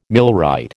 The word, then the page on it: millwright: American English